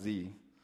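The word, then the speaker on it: ze: Since they're uncomfortable with he or she, they've now preferred to be referred to as Z, pronounced Z. It drops the H from he and the SH from she, replacing those letters with Z, spelled Z-E.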